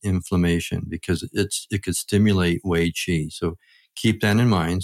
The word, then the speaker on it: Wei Qi: Wei Qi, and that's spelled W-E-I, and then qi is Q-I.